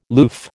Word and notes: loof: American English

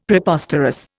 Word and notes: Preposterous: Indian

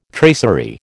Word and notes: tracery: American English

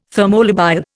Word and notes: thermolabile: Indian

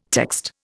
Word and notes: text: Indian

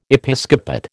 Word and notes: Episcopate: American English

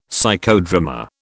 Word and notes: Psychodrama: British English

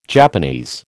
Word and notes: Japanese: American English

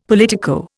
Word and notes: politico: Indian

